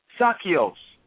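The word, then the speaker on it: sarkikos: Carnal derives from a Greek word uh, is derived from a Greek word which means sakios.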